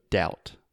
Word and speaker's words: doubt: has a B and we don't say it. So imagine the pronunciation is just like ow, ow. So doubt.